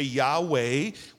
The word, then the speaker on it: Yahweh: And that's YHWH, and what some translators have done, or some other individuals, to kind of help us pronounce this thing, they've added the vowels A and E, so we can say Yahweh, which simply means I am.